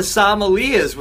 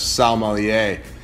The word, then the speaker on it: sommelier: This is probably the worst pronunciation I've ever heard of sommelier. Sommelier. – I thought it was like chandelier with Sam instead of Shan. – Oh, God. Let's earmark this and give him the correct pronunciation of sommelier.